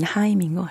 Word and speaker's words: 하임: 저 원래 하임은 이렇게 처음에 읽을 때 사람들이 누구는 헤이엄이라고도 하고 뭐 헤임이라고도 하고 하임이라고도 하고 했는데 보니까 일단 공통적인 발음은 하임인 것 같아요.